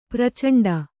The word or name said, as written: ಪ್ರಚಂಡ